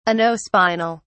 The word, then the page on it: anospinal: British English